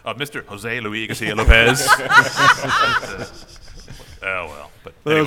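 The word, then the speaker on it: José Luis García-López: I should say, my teacher from the Cooper School brought him over, and his musical pronunciation of his name is like, I cannot literally pronounce his name other than Jose Luis Garcia Lopez.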